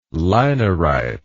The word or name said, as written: linarite